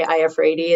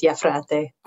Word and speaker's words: Iafrate: In English, I just say Iafredi. It's the easiest way to pronounce it. And in Italian, it's Iafrate.